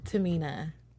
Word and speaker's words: Tamina: Dasha Fuentes and Tamina. Is that how you say it? Okay, Tamina.